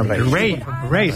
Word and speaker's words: Raye: Ray. Se escribe Raye.